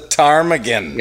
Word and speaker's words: Ptarmigan: The Ptarmigan. – Yeah. The P is silent, like pneumonia.